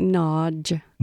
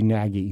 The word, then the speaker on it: Nagy: It's something like Nodge. – Nodge. – But I think like the Nodge. G is not, it's more of a DG type combination, Nodge. Hungarian in terms of that way. But for our Anglophiles like us, it'd be Nagy. – Nagy.